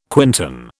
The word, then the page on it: quintan: British English